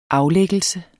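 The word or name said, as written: aflæggelse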